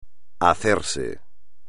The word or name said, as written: hacerse